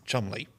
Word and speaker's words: Cholmondeley: You know, a name that we would read as Cholmondeley is Chumley.